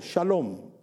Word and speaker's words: shalom: Y la palabra paz en el hebreo significa, o se pronuncia como shalom, ¿verdad?